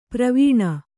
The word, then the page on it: ಪ್ರವೀಣ: pravīṇa